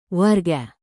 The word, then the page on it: warga: indonesian